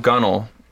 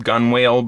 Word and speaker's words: gunwale: I looked up the word gunnel, and then that redirects you to the word... it's spelled G-U-N-W-A-L-E, but it's pronounced gunnel.